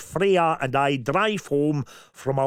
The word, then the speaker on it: Freyja: I would love to hear it as Freya. I think I pronounced that correctly. Yeah, F-R-E-Y-J-A. – Oh. – Would it be a soft J sound? – I think it would be. That sounds like it's Freya with a bit of a garlic bit.